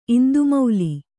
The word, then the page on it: ಇಂದುಮೌಲಿ: Kannada